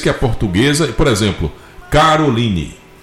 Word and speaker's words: Caroline: Mas a pronúncia em inglês, Caroline. – Caroline.